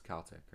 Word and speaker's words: Celtic: It's Celtic, right? – Celtic, yeah. It's one of the very few words in the English language beginning with a C-E that doesn't use the soft... – That has a hard C.